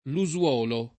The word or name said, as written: Lusuolo